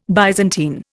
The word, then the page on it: Byzantine: Indian